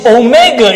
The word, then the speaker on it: omega: And then the next one is omega. It's a long O. You pronounce like O.